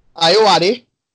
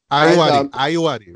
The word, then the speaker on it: Ayoade: Sorry, Ayoade. – Ayoade, right.